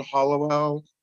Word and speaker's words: Halliwell: I'm on Twitter at Dawn Halliwell One. Last name is spelled like the Spice Girl or the Charmed Ones.